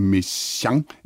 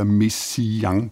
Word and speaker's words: Messiaen: Det andet er så, om man skal høre to eller tre stavelser udtalt, som du skriver, Henrik, er det Messiaen eller Messiaen? Og her på den lille redaktion, Spørg om alt-redaktionen, der har vi lyttet til forskellige indfødte franskmænd, der udtaler navnet for at belære andre, hvordan man gør. Og det lyder nærmest som en mellemting af to og tre stavelser.